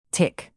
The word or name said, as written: tic